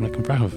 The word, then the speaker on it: Nic An Bhreithimh: I'm very happy to be joined by a very special guest today, Lisa McInfrahov. Am I pronouncing that right? – That's it. You got Excellent.